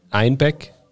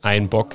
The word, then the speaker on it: Einbeck: It's just that Einbeck, the name of the brewery, was somehow pronounced as Einbock here in Bavaria due to dialect.